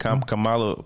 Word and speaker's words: Kamala: Yeah, Kamala Harris, yeah.